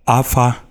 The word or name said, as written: Afa